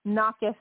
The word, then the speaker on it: knocketh: knocketh is actually spelt with a K in the English language. There's a silent K.